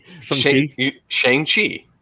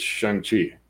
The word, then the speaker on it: Shang-Chi: He specifically goes over it in the movie, I think, for people that are like, how do you say it? He's like, it's Shang-Chi.